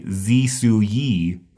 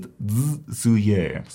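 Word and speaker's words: zsuye (紫苏叶): So please don't say zisuyi. It's the serb is zsuye.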